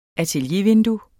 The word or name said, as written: ateliervindue